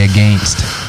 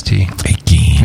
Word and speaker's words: against: You say against, I say against. Okay. Except I spell it A-G-E-N-S-T. – Against.